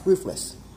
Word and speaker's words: Ruffles: In fact, the most common brand of potato chips here that's not an Ecuadorian brand is Ruffles, which they pronounce roofless.